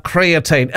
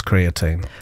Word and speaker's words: creatine: I'm pleased you're here because I've heard a lot about creatine. Am I even saying it right? – That's it. Creatine's okay. Everywhere at the moment.